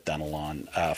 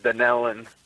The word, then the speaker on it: Dunnelon: I'm going to go with Dunnelon, Florida, watching on YouTube. I hope I said the town right. I have not heard of that town before. – No, actually it's pronounced Dunnelon.